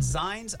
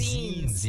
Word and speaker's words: zines: Blue Heron Books and Zines. I always know if it's pronounced Zines. Yeah, because like magazine. – Yeah, I feel like it's zines. – I think you're right, yeah.